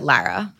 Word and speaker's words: Laura: I pronounce it Lara.